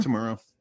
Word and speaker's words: Kitamura: Kitamura.